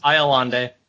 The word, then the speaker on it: Iolande: Iolande, that's how they said it in the cartoon, at least.